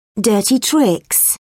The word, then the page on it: dirty tricks: British English